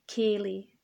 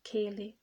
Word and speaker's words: ceilidh: C'erano queste feste leggendarie e balle, ceilidh è una parola gallica, scozzese, ceilidh, che si scrive con tante D, H e L, che non sembra di fare senso in inglese, che è una lingua sterlizzata.